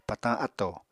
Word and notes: patañ áttò: Apatani

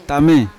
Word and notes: tame: Yawa

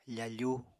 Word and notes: fafu: Apatani

